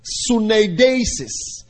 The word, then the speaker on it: suneidesis: The second one is synodesis. Spell it S-U-N-E-I D-E-S-I-S.